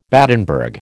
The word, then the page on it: Battenberg: American English